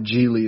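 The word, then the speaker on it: Gigli: It's G-I-G-L-I. Geely is how it's pronounced, though, for some reason.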